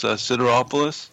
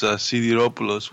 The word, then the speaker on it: Sideropoulos: It's, I guess in English, it's Sideropoulos. In Greek, it's Sideropoulos, which is...